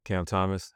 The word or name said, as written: Cam Thomas